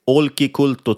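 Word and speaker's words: Olkiluoto: Och då kan man till exempel jämföra med finnarnas nya State of the Art-verk Olki Kulto 3.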